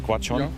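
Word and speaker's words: Gwacheon: So, wir sind heute in Quachon, spricht man es aus, oder? Quachon? – Ja, ja, genau.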